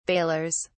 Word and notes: balers: British English

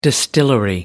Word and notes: distillery: American English

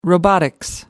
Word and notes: robotics: American English

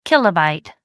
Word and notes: kb: American English

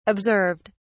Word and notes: observed: British English

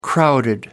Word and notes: crowded: American English

